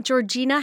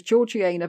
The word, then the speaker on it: Georgiana: Really? Georgiana? It's not Georgina, how we pronounce it over here. – Everybody else would say Georgina or Georgiana, but no, she was Georgiana because they had the upper class at that time had a special, special way of, a special lingo.